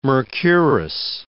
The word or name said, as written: mercurous